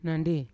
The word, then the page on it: nandi: Yawa